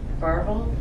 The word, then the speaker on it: Varvel: But, I mean, if you want to read it, the author is Gary Varvel. Is that how you say it? V-A-R-V-E-L.